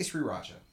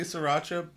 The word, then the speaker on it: sriracha: Is that how you pronounce, how do you pronounce it? – I say sriracha. What do you say? – Oh, God. I say sriracha. – That's what everybody says. – I say sriracha, but I... – There's an R right after like, that S.